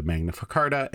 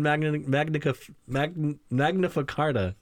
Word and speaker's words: Magna Carta: You added a syllable. – I think I said farta. – You said magnifica carta. Magnifica carta. Like magnificent carta.